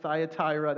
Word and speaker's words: Thyatira: Now, as I said to the first service, you can argue with me all day long on how you want to pronounce that, okay? I'm going with Thyatira.